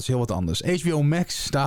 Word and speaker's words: Max: HBO eh, Max, Max, hoe spreek je het uit? Max, toch? – Max. Ja, we pakken het gewoon op zijn Engels.